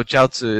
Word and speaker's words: jiaozi: Um, so jiaozi is the Chinese pronunciation.